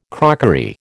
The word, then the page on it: crockery: American English